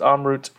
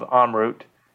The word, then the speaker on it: Amrut: I think if you're in India, it's pronounced Amrut. I bounce back and forth between the pronunciation.